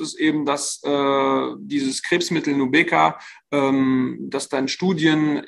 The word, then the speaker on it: Nubeqa: Die sind nämlich sehr optimistisch für das Krebsmittel Nubeqa. Ich hoffe, ich habe es richtig ausgesprochen. – Ich hätte es genauso ausgesprochen, sehr gut.